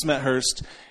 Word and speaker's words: Smethurst: Matt Smethurst.